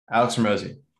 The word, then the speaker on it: Hormozi: Alex Hormozi.